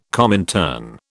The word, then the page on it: Comintern: British English